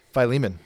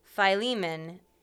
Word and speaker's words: Philemon: Well, because I feel like there's a lot of different ways that people pronounce his name. – Correct. – So I know you say Philemon, Philemon. – Philemon is the typical American way to say it. I've never heard people not pronounce it Philemon. – Until I took Greek, and then Philemon would be one way. – Philemon. Philly. Philemon, and that's what, yep.